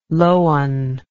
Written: lowan

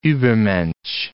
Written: Übermensch

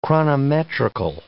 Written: chronometrical

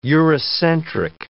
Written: Eurocentric

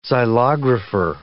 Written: xylographer

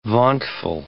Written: vauntful

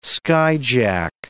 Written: skyjack

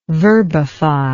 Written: verbify

